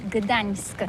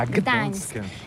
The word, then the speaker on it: Gdańsk: Alors d'abord, comment prononce-t-on bien? Ça s'écrit G-D-A-N-S-K. On dit Gdansk ou Gdansk, on prononce le G ou pas? – Oui, c'est pas très facile pour des étrangers, mais remarque bien que la langue polonaise, c'est la langue où on prononce chaque lettre qu'on écrit. C'est pourquoi on dit Gdańsk.